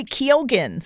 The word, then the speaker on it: Keoghan: The star of the movie Saltburn is Barry Keoghan, spelled K-E-O-G-H-A-N.